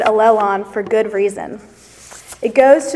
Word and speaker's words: allelon: In Greek, this term is pronounced allelon.